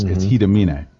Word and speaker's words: Hiramine: When you look at it, it says Hiramine. – Mm-hmm. – But because it's Japanese, it's Hidamine.